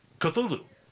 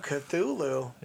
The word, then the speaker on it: Cthulhu: Cthulhu. I said it right. Oh, my God. – Yeah, you did. – I butcher names. – Wow, Cthulhu.